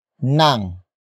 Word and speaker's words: nang: It is introduced by the next word, nang.